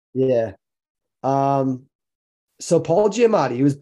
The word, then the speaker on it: Giamatti: Paul Giamatti.